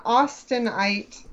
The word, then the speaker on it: Austenite: I am not an Austenite, is that how you say it? – Sure, yeah.